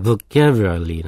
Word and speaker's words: vocabulary: でも、英語の場合は、V の後のオは、オって発音しないのね。うなのね。だから、 vocabulary なのね。 vocabulary。その、もちろん、 vocabulary の、後ろの bulary の、ビューも、ビューって発音したら、ブーなのね。